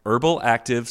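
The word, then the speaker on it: Urbal Active: Herbal Active. So think of the word, it's spelled, think of the word urban, but with an L. – Sure. – So URBAL, and then active without an E.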